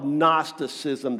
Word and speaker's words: Gnosticism: What was going on in Colossae was an early form of what's called Gnosticism. The G is silent. Gnosticism comes from the Greek word gnosis, G-N-O-S-I-S in our English letters, and gnosis means knowledge.